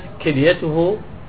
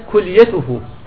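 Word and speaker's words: كُلْيَته: الخطأ أن تقول أصيبت كليته بالتهاب حاد. أصيبت كليته بكسر الكاف بالتهاب حاد. والصواب أن تقول أصيبت كليته بضم الكاف.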